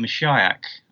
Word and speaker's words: Meshiaak: This is a band called Meshiach. M-E-S-H-I-A-A-K is how the band name is spelled.